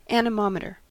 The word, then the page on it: anemometer: American English